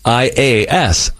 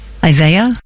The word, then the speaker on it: Isaias: Isaias. I say Isis. – Isaiah? – That's not Isaiah.